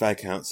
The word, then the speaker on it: Viscount: I'm just like, did you mean Viscount? – I'm sorry? It says right here, Viscount. – Yeah, it's pronounced Viscount, sorry.